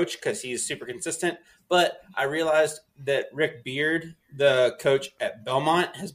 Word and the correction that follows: Byrd: I'm gonna go Rick Beard uh, Rick Byrd. How do you say it, Rick Beard? – Bird? Rick Rick Rick Byrd,